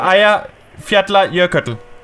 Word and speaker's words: Eyjafjallajökull: Og det var så Eyjafjallajökull.